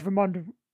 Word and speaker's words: Ramonda: Oh my god, Ramonda rhymes with Wakanda.